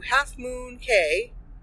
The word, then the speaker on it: Half Moon Cay: And we'll be leaving Miami and heading to Half Moon Cay.